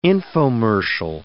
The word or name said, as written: infomercial